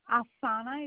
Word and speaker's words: Afsana: It's Afsana.